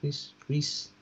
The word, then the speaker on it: Kreese: Did I say that right? Kreese? – Apparently, yeah.